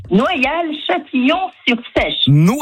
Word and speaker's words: Noyal-Châtillon-sur-Seiche: Noyal-Châtillon-sur-Seiche.